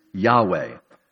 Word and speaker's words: Yahweh: What it actually was written was Jehovah, or even more accurately, the way it was probably pronounced is Yahweh.